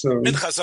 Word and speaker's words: Minchas Avraham: Rav Avraham Yankiv was also the mechaber of the Sefer Matnas. Am I saying it right? Matnas Avraham. – Minchas Avraham. The Minchas Avraham.